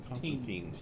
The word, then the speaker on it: Constantine: Constantine. – No, Tyne. That's how the Brits say it.